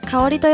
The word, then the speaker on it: 香織: 名前は漢字で書くと果実の果、麺朝の朝、理科の理と書いて香織と読みます。